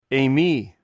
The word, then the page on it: eimi: greek